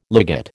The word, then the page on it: ligate: American English